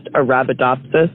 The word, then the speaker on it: Arabidopsis: The plant that they sent to space is pronounced Arabidopsis.